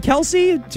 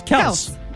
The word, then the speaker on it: Kelce: And Kelce, too many syllables. Kelce.